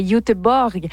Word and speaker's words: Göteborg: Selon la prononciation exacte, ce serait Göteborg. Anaïs Barbeau-Lavalette sera de la partie.